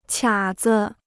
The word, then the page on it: 卡子: qiǎ zi